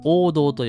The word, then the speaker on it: 黄銅: これね、黄色い銅と書いて、黄銅と読みます。